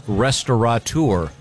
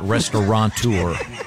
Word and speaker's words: restaurateur: The most mispronounced is restaurateur. – Mm-hmm. – There's no N. It's not restaurateur.